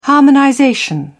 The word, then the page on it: harmonization: British English